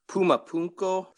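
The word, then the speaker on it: Puma Punku: Puma Punko with my Latino accent. That's excellent pronunciation.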